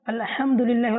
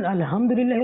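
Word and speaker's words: আলহামদুলিল্লাহ (الحمد لله): তো বাংলার মধ্যে তো ওখানে লেখা থাকবে না, এখানে হ্যাঁ বাংলার জন্য হ একই, কিন্তু আরবির মধ্যে আলাদা আলাদা, একটা হ্যাঁ আর একটা হা, আর দুইটার উচ্চারণ আলাদা আলাদা, মাখরাজের সাথে উচ্চারণ হবে। আপনি নামাজ পড়তে দাঁড়ালেন, শুরুতে বললেন আলহামদুলিল্লাহ রাব্বিল আলামিন, এটা হচ্ছে শুদ্ধ। আর যদি বলেন আলহামদুলিল্লাহ রাব্বিল আলামিন, অর্থাৎ আপনি আলহামদুকে আলহামদু পড়ছেন, হা কে হা পড়ছেন, আপনার নামাজই হচ্ছে না।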